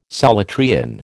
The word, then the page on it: Solutrean: American English